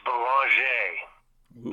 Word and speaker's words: Belanger: It's Belanger, French-Canadian. – Like lingerie? – No, kinda, yep.